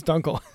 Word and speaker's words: Dunkel: He doesn't know how to say the word. Dunkel? – D-U-N-K-E-L. – Dunkel. – It's Dunkel. – Yeah, it's Dunkel, man.